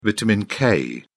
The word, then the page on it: vitamin k: British English